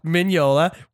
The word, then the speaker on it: Mignola: But I do want to correct you on your pronunciation of Mike Mignola's name. It is Mignola.